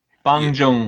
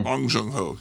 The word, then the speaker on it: Bong Joon-ho: Bong Joon-ho. – Yeah, Bong Joon-ho, yeah.